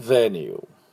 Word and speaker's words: venue: であと会場のことはベニュー、あまり聞き慣れない言葉と思いますが、ね、 V でベニュー、 V-E-N-U-E です、ベニューで、ベニュー、これニューって伸ばすだけですね。メニューと一緒です。はい、フランス語系のスペルですね。では、私の後について発音しましょう。競技会場です。 Competition venue.